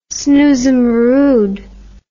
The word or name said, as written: snoozamorooed